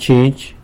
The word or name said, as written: chinzh